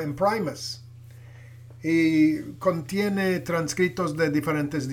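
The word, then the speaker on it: Imprimis: Y luego sale un profesor de allá del colegio y dice que se pronuncia Imprimus y él explica su significado.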